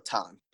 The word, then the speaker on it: Tan: It's it's uh like John, but with the T. So Tan.